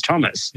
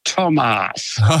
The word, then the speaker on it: Tomas: It's spelled C-O-M-A-S, which I suppose you'd say is Thomas. – Yeah. – But the way it's pronounced is Tomas.